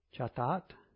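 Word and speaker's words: Chatat (חטאת): And then there's the sin offering, which is the shataz or shataat. Excuse me. That's a T, not a Z. Sometimes my T's look like Z's. Chatat.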